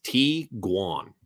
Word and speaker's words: Tiguan: How is Tiguan pronounced? This combination of German words is pronounced Tiguan.